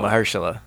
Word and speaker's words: Mahershala: How you say it again? – I don't know. – Mahershala. – Mahershala. For real? Mahershala.